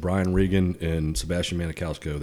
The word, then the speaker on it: Maniscalco: Maniscalco is.